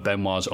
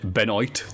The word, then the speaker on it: Benoit: And here comes Chris Jericho, the spar one of Benoit's only good promos. Jericho pronounces it Benoit, which is what I used to before I watched TV. – Same. – And went, oh, it's Benoit.